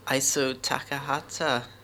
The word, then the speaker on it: Isao Takahata: It's a Japanese animation studio, which has produced many classics over the years, and some famous directors like Hayao Miyazaki and Isao Takahata.